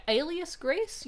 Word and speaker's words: Alias Grace: But she was also in Elias Grace. Is that how you pronounce it? Alias Grace? – Alias Grace? – Yeah.